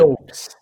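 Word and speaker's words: Doubs: Romeu Dubs, wide receiver, é Dubs, né, que se pronuncia? – Dubs.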